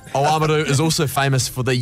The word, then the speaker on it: Oamaru: Or as it's pronounced, Oamaru down there.